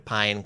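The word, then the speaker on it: Pyne: There's a historian named Stephen Pine who National Geographic talked to. It's spelled P-Y-N-E. I know, it sounds like pine tree.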